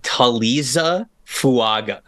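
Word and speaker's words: Taliese Fuaga: His name is Taliza Fuaga.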